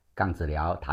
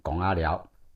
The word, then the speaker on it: 槓子寮: 杠子寮台语念成拱阿寮，以前平埔族捕鹿的陷阱叫做拱阿，放置陷阱的猎寮就叫做拱阿寮。东北角的贡寮台语也是叫拱阿寮，以前是山雕社的猎场跟猎寮所在地。